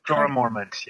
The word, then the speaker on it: Jorah Mormont: Jorah Mormont, yes.